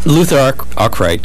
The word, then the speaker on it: Luther Arkwright: Now, was that Luther Arkwright? Did I pronounce it right? – Yeah.